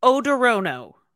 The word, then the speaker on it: Odorono: Odorono. – It's spelt O-D-O-R-O-N-O. It looks like odorono, but it's odor. Oh, no.